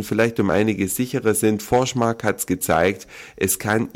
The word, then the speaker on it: Forsmark: in Forsmark oder Forsmark, wie es wohl richtig ausgesprochen wird, nahezu an den Rand einer Katastrophe geführt.